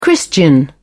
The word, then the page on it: christian: British English